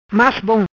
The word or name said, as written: Bom-Mas